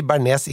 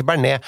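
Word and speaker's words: béarnaise: Men jeg mener at ord som Bernays, der skal man si Bernays, ikke Bernet. Ja. Fordi Bernet, det et forsøk på å snakke fransk, men mislykket.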